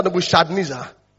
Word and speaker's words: Nebuchadnezzar: Nebuchadnezzar, Nebuchadnezzar.